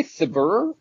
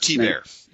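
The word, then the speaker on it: Thibert: Did you say Thibber? I thought it was Thibbert. I'm not sure how to pronounce the artist's name. – Art T. Bear is how it's been told. I'm sorry, is how I've been told to pronounce it.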